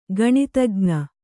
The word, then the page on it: ಗಣಿತಜ್ಞ: gaṇitajña